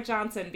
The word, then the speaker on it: Janssen: I think you pronounce it more Johnson because she's, European.